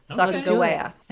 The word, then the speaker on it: Sacagawea: But our wonderful Sacagawea, who I always thought it was pronounced Sacagawea, and then I realized that actually it's pronounced Sacagawea, the hard G sound.